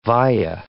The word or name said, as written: via